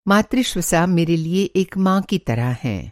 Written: मातृ-ष्वसा

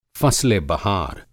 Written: फ़स्लेबहार

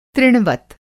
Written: तृणवत

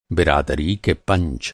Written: पंच

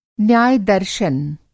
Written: न्याय-दर्शन

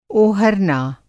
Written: ओहरना

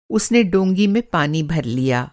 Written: डोंगी

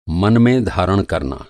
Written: धारण

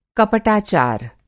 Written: कपटाचार

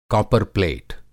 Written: कापरप्लेट

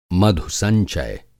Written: मधुसंचय

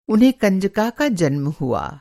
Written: कंजका

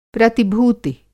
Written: प्रतिभूति

